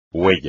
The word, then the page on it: huella: espagnol